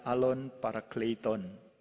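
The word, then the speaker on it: alon parakleiton (ἄλλον παράκλητον): Sorry, saya sengaja pakai ada bahasa Yunaninya itu bacanya alon parakleiton. Bukan bahasa Jawa ya, alon-alon asal kelakon, agak mirip soalnya.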